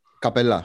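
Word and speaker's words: Capela: Τα τελευταία χρόνια ο Harden έχει τσακωθεί ακόμα και με τον Καπέλα. – Σωστό. – Και είναι. – Ναι, και ο Embiid δεν είναι Καπέλα. Εγώ θα κρατήσω την ελβετική προφορά. – Καπελά, σωστό.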